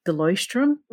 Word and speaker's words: Leuchtturm: And then on thicker paper like the Leuchtturm.